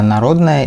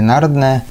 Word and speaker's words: Народная: Я считаю, что правильно говорить «гора народная», и, скорее всего, это так и есть, это так и подтверждается исторически. Но в народе прижилась народная, поэтому как бы можно говорить и так, и так, наверное.